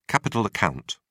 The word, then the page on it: capital account: British English